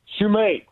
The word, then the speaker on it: Shumate: And we have Caleb Shumat. Is that how you say it? That's probably not it. Shumate. I think it's Shumate. What is it, Caleb? Shumat or Shumate? – Caleb Shumate. – Shumate. I should have went with that in the first place.